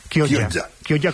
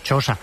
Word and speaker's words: Chioggia: Gioia, Chioggia, como dicen ellos, Chosa, que está allá fuera, que es una especie de pequeña Venecia, pero esto ya está, digamos, es una peninsulilla.